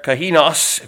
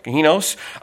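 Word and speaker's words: kahinos (καινός): it's, I even pronounced it for you there, kahinos, if you would, kahinos.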